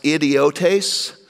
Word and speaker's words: idiotes: In the original language, the word is actually pronounced idiotes, from which we get idiots, but it wasn't quite as insulting in the original language, but it means they just hadn't really been schooled.